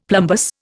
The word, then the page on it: plumbous: Indian